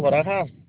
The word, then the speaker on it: Barajas: Arturo Barajas.